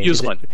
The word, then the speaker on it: Uslan: I don't know how to pronounce his last name. – Uslan. Right. – Uslan.